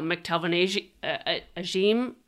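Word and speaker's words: McTelvin Agim: I mean, it's a almost entirely veteran group outside of, you know, Ojemudia and, you know, McTelvin Ajim, I believe. Isn't that how you say it? Yeah.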